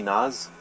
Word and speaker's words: Naz: You can just call me Naz for short, N-A-Z.